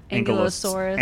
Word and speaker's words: Ankylosaurus: Ankylosaurus.